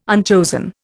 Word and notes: unchosen: Indian

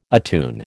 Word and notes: attune: American English

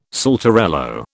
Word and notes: saltarello: British English

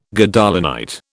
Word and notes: Gadolinite: British English

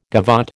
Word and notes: gavotte: American English

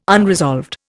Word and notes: unresolved: Indian

